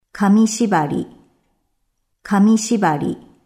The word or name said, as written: Kami shibari